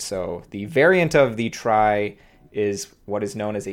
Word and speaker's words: trie: But this paper introduces a variant of I'm not sure how popular it is, a data structure called a try. I believe that's how I pronounce it. But I've heard some people call it a tree, which is confusing because that's also a sort of other node-based data structure. But it's spelled T-R-I-E to disambiguate. And the variant of it is called a dog, which is D-A-W-G.